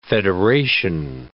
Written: federation

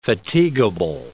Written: fatigable

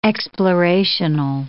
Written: explorational

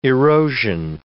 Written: erosion

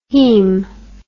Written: haem

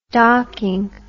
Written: docking

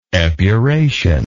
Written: epuration

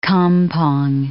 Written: kampong

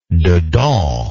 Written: dedans

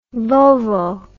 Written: vulvo

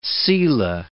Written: selah

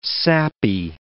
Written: sappy

